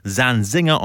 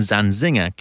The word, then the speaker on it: Zanzinger: For example, William has the stress on the W, Zanzinger on the second syllable, not the final one, and Hattie Carroll has both the stresses on the first syllables.